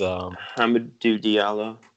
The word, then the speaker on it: Hamidou Diallo: It is Hamidou Diallo.